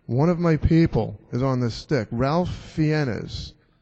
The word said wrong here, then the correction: Ralph Fiennes: if I'm pronouncing that right, was the guy in... – No, it wasn't Ralph. I know he's right, though. Rafe. There it is. Rafe Fiennes.